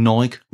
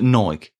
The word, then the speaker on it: Nike: Most people thought that Adidas and Nike were above them. But there's an error, because Don McIntyre also says that Nike is pronounced Nike.